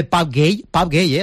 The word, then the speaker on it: Pape Gueye: Escuchado ya el saludo de Pape Gueye, así se pronuncia, vamos ahora a un sitio donde él jugó hace 2 temporadas, que es el Sevilla.